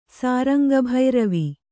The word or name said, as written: ಸಾರಂಗಭೈರವಿ